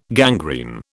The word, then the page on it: gangrene: British English